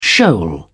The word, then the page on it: shoal: British English